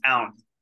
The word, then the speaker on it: Aoun: Yeah, brother, it's Aoun.